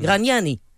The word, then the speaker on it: Gragnani: Gragnani, quand il le prononce son nom, il le prononce à l'italienne. Mmh. Gragnani.